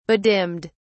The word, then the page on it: bedimmed: British English